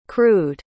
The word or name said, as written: crewed